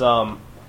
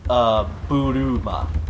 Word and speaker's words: Bulma: It's pronounced Buruma. – Bloomer. – Bloomer. – Bloomer. – Like think of balloon except with the n at the end.